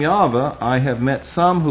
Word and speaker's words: Yahweh: And so consequently, they then, as well as today, will not use the name Jehovah, or the perhaps more correct pronunciation, Yahweh.